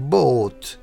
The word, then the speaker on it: bought: أصله سهل المضارع اللي هو buy يشتري buy والماضي اللي هو أصبح bought أصبح ست حروف وفيها جي اتش في الوسط ما تنطقش تنطق بوت اشترى.